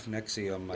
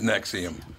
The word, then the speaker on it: NXIVM: How do you say that? – Nexium. – Oh, it is Nexium.